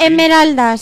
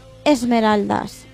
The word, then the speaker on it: Emeraldas: Emeraldas, sí, realmente es Emeraldas. Pero aquí en España, por comodidad, le decimos Esmeraldas.